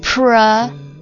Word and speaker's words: proliferate: 好了，这个单词什么单词呢？Proliferate. Proliferate. P-R-O-L-I-F-E-R-A-T-E. Pro 我们分开来记，pro P-R-O，中间是一个 life。L-I-F-E 然后最后是一个 rate 这个单词 R-A-T-E 读音的话当然不是说 life 和 rate 啊，读音的话 proliferate proliferate 不是 life